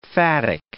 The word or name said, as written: phatic